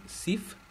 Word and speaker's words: Xiph: Finalmente, el formato OGG, que en inglés sería OGG, creado en 1993 por la fundación SIF, eso se escribe X-I-P-H, que fue lanzado como proyecto open source y como alternativa para códecs libres de licencia.